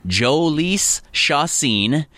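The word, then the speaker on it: Jhoulys Chacín: Pat, we talked in the first segment about the Twins' new addition, which we decided is pronounced Joelys Chacin.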